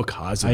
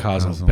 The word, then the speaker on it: Casel: Although a lot of English speakers call him Kazel sometimes, I believe the proper pronunciation would be Kazel.